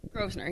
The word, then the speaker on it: Grosvenor: Grosvenor, yes.